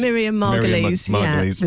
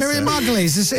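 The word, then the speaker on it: Miriam Margolyes: Oh, Marion Margolies. – Miriam Margolies. – Yeah. Marion Mugalas. Do you know what? – What's her name? – I've never known how you pronounce her name. Marion Margolies. – Miriam Margolies. – Yeah, Miriam Margolies.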